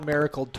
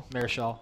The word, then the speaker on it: Marichal: Juan Marichal twice did it back in the 60s. – Marichal. – I knew I was saying that wrong.